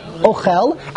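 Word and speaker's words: ochel (אוכל): It's milara. You put the emphasis on the end, the chof, ochel.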